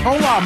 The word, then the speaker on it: hola: Right, but hola, the H is silent, so it's O-L-A.